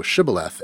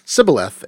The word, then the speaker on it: Shibboleth: "Say you now Shibboleth," and the spies were unable to pronounce the sh sound, and they said Sibboleth and were put to death.